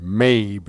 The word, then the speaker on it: Mabe: Scissors and Paper by Heather Berry and Tony, I think he probably pronounces it Mabe, M-A-B-E, Heather Berry and Tony Mabe.